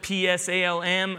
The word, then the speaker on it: Psalm: Um, Psalm spelled P S A L M, a silent P.